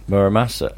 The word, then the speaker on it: Muramasa: Muramasa. – I think he wants. – Murasama.